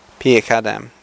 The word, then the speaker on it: Pierre Cardin: I refuse to be grateful, she says to Perry Curry. Perry Curry. Was it Peter Cardin? Perry Cardin. Pierre Cardin. Okay, this French is really difficult for us Aussies to pronounce.